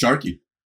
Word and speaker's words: Sarki: Is it Sarki or Sharky? – It's Sharky.